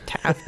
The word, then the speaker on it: Taft: Taft rhymes with draft.